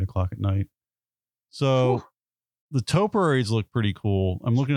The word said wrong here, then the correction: topiaries: I always laugh. – How do you say it? Topiaries.